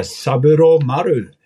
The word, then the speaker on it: Saburomaru: Men eh, Saburomaru heter det. Bra uttal, kan jag säga.